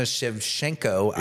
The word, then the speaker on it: Shevchenko: Oh, that's right, Valentina Shevchenko. Yep. I finally said it right because I read it as I was saying it.